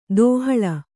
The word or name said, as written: ದೇಹಳಿ